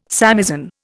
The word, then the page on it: samisen: Indian